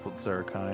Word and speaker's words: Slitzer Kai: The list Zerikai, my name is Slitzer Kai, no H, or shortened to Slitz. – Actually, I think it is Slitzer Kai, and I've just been mispronouncing it all these episodes.